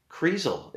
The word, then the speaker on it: Kreisel: It's not Dennis pencils or Dennis Krenzels or whatever I was saying. It's Kreisel. It's not that hard. It should be easier than my last name.